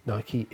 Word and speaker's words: Nike: Nike is correct. Yeah, this is one of those that I had the debate with my daughter. She still claims it's Nike.